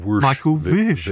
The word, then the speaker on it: Michael Wisch: Michael Vish, who was actually very particular, by the way, about the pronunciation of his name, Andy.